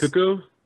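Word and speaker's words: Cucco: How do you say their name? Does anyone know? – I think it's Cuckoo. – One person at a time, please. – Kukos. – I believe it's pronounced Kukos.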